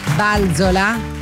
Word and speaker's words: Balzola: Balzola, l'ho detto bene Giuliana, l'accento l'ho messo bene.